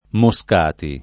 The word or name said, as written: Moscati